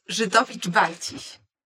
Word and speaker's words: Żydowicz-Barciś: It's Żydowicz, Barciś.